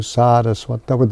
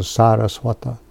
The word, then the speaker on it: Saraswata: I'm offering obeisances not to Saraswati, but to Saraswata. There's a long A, because Prabhupada is a Saraswata.